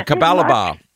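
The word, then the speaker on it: Capalaba: How do I pronounce where you're from in Queensland? – Kapalabar. – Yeah.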